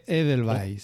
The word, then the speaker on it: Edelweiss: Muy identificativa de Suiza, que es la flor de Edelweiss.